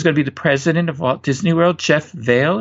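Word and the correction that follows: Vahle: Is that how you say his name? Jeff Vale? – Valley. – Valley. Oh, how interesting. Interesting spelling of it.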